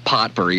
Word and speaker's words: potpourri: It's spelled P-O-T-P-O-U-R-R-I, but you don't say the T. It's not potpourri. It's potpourri.